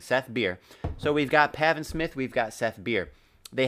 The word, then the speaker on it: Pavin: but much better, Pavin Smith. What the fuck is that? I'm assuming I'm pronouncing it right. It's literally just Gavin with a P.